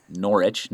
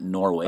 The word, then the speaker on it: Norwich: I don't know if you know, but it's pronounced Norwich, not Norwich.